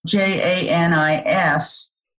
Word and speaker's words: Janis: Well, let me first say that um, my name is spelled J A N I S Butlar, B U T L A R, not J A N I C E.